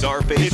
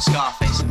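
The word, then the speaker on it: Czarface: Zarface. – It's Z-Z-A-R. – Was it Zarface, not Scarface? – I can't ever seem to say it right.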